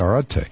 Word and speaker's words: Arete: I guess that's how you say it, Arete. It's A-R-E-T-E. It's the ancient Greek word for virtue.